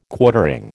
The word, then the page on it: Quartering: American English